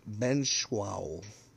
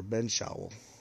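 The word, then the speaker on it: Benzschawel: So that is pronounced Benshawl, or Benshawl. There it is, Benshawl.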